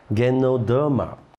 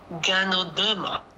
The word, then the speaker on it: Ganoderma: Ganoderma。 – 纠正一下，应该念 Ganoderma。